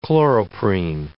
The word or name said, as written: chloroprene